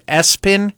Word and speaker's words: ESPN: Someone said the name ESPN, but it's pronounced Espen.